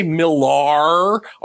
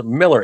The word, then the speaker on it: Millar: People who say Millar are going to be the first against the wall when the revolution comes. The man's name is Miller.